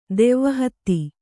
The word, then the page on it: ದೆವ್ವಹತ್ತಿ: kannada